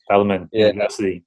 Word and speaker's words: Bellarmine University: Excuse me if I'm saying this right, Bellarmine University, is that right? – Bellarmine University.